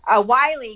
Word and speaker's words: Wiley: Wiley, yes, yeah.